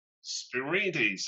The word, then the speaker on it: Spyrides: Spyrides, there you go.